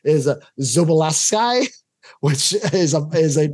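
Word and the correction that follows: Szoboszlai: And also Soboslai.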